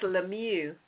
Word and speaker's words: Lemieux: Yeah, that's that French pronunciation. That's Lemieux.